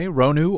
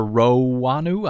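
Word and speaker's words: Roanu: Okay, Ronu or Rowanu, I don't know.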